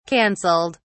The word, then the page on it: cancelled: British English